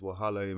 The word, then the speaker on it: wahala: Because I say, when you break up with someone, generally there's wahala involved. Did I pronounce it right? – You said it correctly, man.